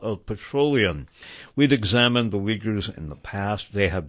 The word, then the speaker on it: Uyghurs: There has been a lot of publicity about the Uyghurs, or some pronounce that Uyghurs. You will see that variously spelled as U-I-G-H-U-R or U-Y-G-H-U-R.